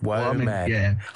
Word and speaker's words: WOMAD: The other shows like that we was talking about too, but like WOMAD, I don't know if WOMAD. I wasn't sure how to pronounce that. Well, I thought it was like WOM Adelaide or something. – WOM Adelaide. It's just, yeah, World of Music and Dance.